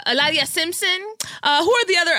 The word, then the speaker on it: Hilaria: Hilaria Baldwin.